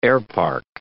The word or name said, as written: airpark